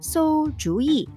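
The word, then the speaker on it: 소주의 (馊主意): 그거에 주어 이 삼성 사성을 넣어주면 돼요. 그 한국 발음 주의에 삼성 사성을 넣어주면 되거든요. 그래서 소주의 하면 시시한 생각이란 뜻이에요.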